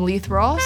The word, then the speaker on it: Leith Ross: We got some, we're doing some Leith Ross. Is that how you say it? – Oh, yeah. – Leith. Leh. Those who know. – R-Y-K-Y-K.